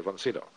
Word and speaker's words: von Sydow: And I say, von Sydow.